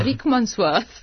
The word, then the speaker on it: Rickmansworth: Rickmansworth.